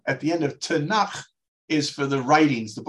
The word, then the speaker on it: Tanakh: No, the, the Hebrew acronym is pronounced Tanakh, which is standing for the T is for Torah, the five books of Moses.